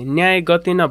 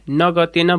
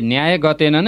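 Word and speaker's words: न्यायगतेन: गुरु, रुसाले न्याय गतेन भन्नुपर्ने नगतेन भनेको सुने त मैले। – सही कुरा गर्नुभयो तपाईँले, रुसाले न्याय गतेन नै भन्नुपर्छ।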